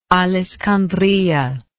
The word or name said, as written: al iskandariyah